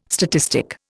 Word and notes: statistic: Indian

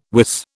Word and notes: wis: British English

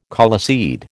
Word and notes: coleseed: American English

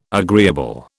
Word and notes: agreeable: British English

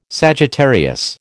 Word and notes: Sagittarius: American English

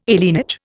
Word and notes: alienage: Indian

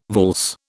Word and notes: valse: British English

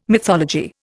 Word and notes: mythology: Indian

